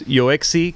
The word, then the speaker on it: Yoexi: This gentleman's name is Yoexi Quintanilla. Yoexi is spelled Y-O-E-X-I.